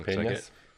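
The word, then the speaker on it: peñas: I think I'm pronouncing that right. Piñas. – Mm-hmm. Piñas. Like yeah.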